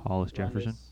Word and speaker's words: Hollis Jefferson: You've had Hollison Jefferson. Sorry, I can't ever say his name. – Hollis Jefferson.